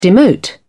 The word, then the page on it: demote: British English